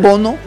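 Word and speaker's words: Bounou: Es que lo escriben Bono, pero lo pronuncian Bono. – No. Como... Bono, no Boner.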